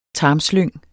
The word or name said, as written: tarmslyng